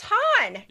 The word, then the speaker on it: Han: It's Han. Yeah. He introduces himself as Han, and it's Tatooine because the people who live there say, I live on Tatooine.